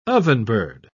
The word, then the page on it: ovenbird: British English